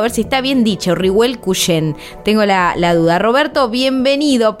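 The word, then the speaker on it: Riebel Kuchen: La pronunciación es una pronunciación de los alemanes del Volga y es Rihuel Cuyén.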